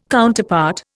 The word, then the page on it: Counterpart: Indian